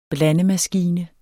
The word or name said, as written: blandemaskine